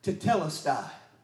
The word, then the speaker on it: tetelestai: It's the Greek word tetelestai. And that word tetelestai in the original Greek means to bring to completion, to bring to a conclusion, to accomplish, to fulfill, and to finish.